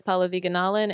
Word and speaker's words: Pallavi Gunalan: I'm on Instagram at Real RHP, R-E-A-L-R-H-P. Thank you. – Hi, my name is Paula Viganalan, and all my social media is my name, which is spelled P-A-L-L-A-V-I-G-U-N-A-L-A-N.